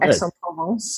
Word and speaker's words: Aix-en-Provence: It's in Aix-en-Provence, so that's how you pronounce it.